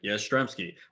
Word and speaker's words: Yastrzemski: I'm like, Yastrzemski.